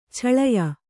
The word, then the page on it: ಛಳಯ: chaḷaya